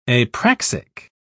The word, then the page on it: apraxic: British English